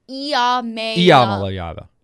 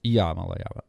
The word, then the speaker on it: Iamaleava: Is it Ia or Ya? – Iamaleava. Yes. – Iamaleava. Okay.